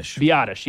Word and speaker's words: Biadasz: But in free agency, there was, how do you say Tyler Biadiaz? How do you say it? – Biadasz, yeah.